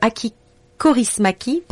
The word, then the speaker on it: Aki Kaurismäki: un film de Aki Kaurismäki, pardon pour la prononciation. – Bien prononcé, on voit que tu t'es entraîné.